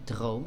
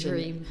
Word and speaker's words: Droom: Dat is een droom. Ik ga het op zijn Engels uitspreken, maar het is gewoon droom. – Niet dream.